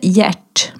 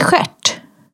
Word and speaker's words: Gert: Och där uttalade man inte Gert, men det stavades liksom G-E-R-T. – Ja, okay. – Utan där uttalar man det skärt.